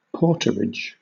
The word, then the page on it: porterage: British English